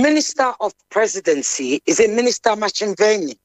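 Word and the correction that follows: Ntshavheni: Nchaveni. – Did I pronounce it right? – Minister and the President Nchaveni, yes.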